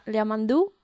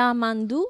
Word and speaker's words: Yamandu: Yeah, Yamandu Costa. – Okay, I think I'm mis- I'm mishearing the the the pronunciation. Yamandu.